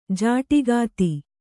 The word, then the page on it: ಜೋಡುಖಾತೆ: kannada